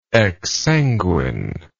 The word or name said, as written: exsanguinity